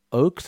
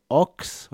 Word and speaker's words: Ochs: His name is Phil Oakes, or Phil Ox. I'm never sure how you pronounce it. O-C-H-S.